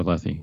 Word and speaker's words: Lethe: How do you say it? Lethe? – Yeah, the River Lethe.